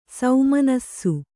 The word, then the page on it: ಸೌಮನಸ್ಸು: saumanassu